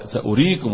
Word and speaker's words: سأريكم: دية تنطق ازاي ايوه يعني سين بعدها همزة وبعدها ايه واو مش كده ودي ايه ها تنطق ايه في الوسط ولا في الوقف ها قولوا الصراحة في الاثنين مش كده ولا ايه معلش ما هو واحد ما بيشوفش يعني هتقول له في صفر مش بيقول لك ما بيشوفش كفيف يبقى سأريكم يبقى بنطق هنا بقى هو سأريكم هو بقى سأريكم هكذا نعم سأصرف عن آياتي الغي هكذا بقى الثلاثة ياء ازاي؟